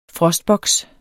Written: frostboks

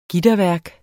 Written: gitterværk

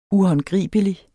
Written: uhåndgribelig

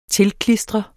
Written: tilklistre

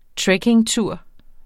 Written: trekkingtur

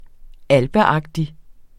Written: alpeagtig

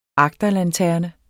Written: agterlanterne

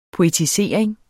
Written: poetisering

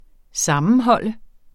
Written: sammenhold

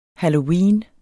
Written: halloween

